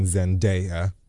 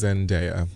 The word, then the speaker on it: Zendaya: He's been asked about the Lifetime movie that everyone is talking about starring Zendaya. Yes, I realize I said her name incorrectly 500 times last week. – Oh, it's not Zendaya? – Apparently it's Zendaya.